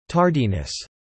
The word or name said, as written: Tardiness